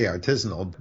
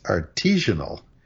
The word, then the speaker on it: artisanal: Well, I say artisanal, but the one that you don't want, and which I do hear waiters saying, is artisanal.